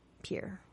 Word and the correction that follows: pyre: Like a fire. – But yes, pyre.